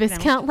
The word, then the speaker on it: Viscount: By the way, Viscount is spelled V-I-S-C-O-U-N-T. – Mm. – Looks like Viscount, like discount, but it's spelled, or it's Viscount like discount. – Yeah. It's pronounced Viscount.